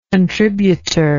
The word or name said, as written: contributorial